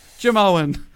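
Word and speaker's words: Jamoan: Jim Owen. – Like the comedian Jim Owen, it's spelled like that? – Well, it's spelled slightly differently. It's J-A-M-O-A-N, but like Jim Owen.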